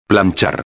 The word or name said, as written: planchar